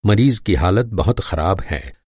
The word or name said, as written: मरीज़